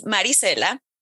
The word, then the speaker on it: Maricela: How do you pronounce it? – Of course. It's Maricela. – Maricela. I like that. That's not okay. Yeah. So it's Maricela. Yeah, I can pronounce that. You actually did it slightly differently with it. Yeah, do it the way you did it a few minutes ago. That sounds even better.